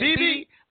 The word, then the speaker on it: Bibi: B-I-B-I is Bibi. – That's how it's pronounced. – I don't care how you pronounce it. – In English, it's B-I-B-I might be that.